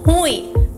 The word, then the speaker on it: ruim: A palavra ruim é uma oxítona, mas muitas pessoas pronunciam como paroxítona. Ruim. Por exemplo, deu ruim. Mas a pronúncia correta da palavra é ruim, e não ruim.